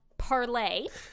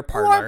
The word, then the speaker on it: Parler: And then I guess another big event is to do with an alternate social media platform that was started called Parlay. – Parlay or Parlor. – Or Parlor. Yeah. – It is from the French word parlay. – Okay. – But it's mostly pronounced parlor. Most people pronounce it parlor.